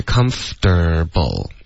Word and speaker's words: comfortable: 哎，这个舒服的、舒适的，对，它其实是三个音节 comfortable。 OK，而不是 comfortable，这个 comfortable 的这个四个音节的发音呢是比较英式的，然后英式口音呢其实用的现在也就是两个都会用，三个音节的四个音节都会说，在美国几乎大家都是用三个音节的 comfortable，